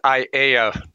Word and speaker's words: Aiea: You got the name right, but it's Aiea. It's so close enough. We're the only, as I mentioned last time, we're the only city in the United States without a consonant, so we can't play Wheel of Fortune.